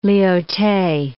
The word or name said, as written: Lyautey